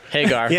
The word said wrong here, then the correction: Hagrid: Yeah, Hagrid, I said it wrong.